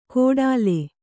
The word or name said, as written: ಕೊಡಲೆ